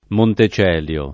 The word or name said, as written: Montecelio